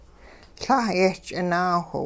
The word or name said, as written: La' yehch'inaawh